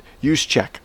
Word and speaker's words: Juszczyk: It's pronounced Juszczyk? – Juszczyk, okay, whatever.